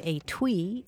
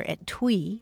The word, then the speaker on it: étui: If you do enough crossword puzzles, you're sure to come across the word étui or étui. It's spelled E-T-U-I, and it means a small case for sewing needles or other little items.